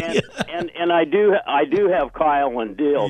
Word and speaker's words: Keil: Oh, Kyle. K-E-I-L and Dalidge. They were two Jewish Messianic believers. – Oh, I pronounce him Kyle and Dilts.